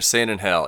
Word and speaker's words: San Angel: How do you really pronounce it? I'm doing my best. – You had it. San Angel.